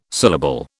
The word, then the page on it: syllable: British English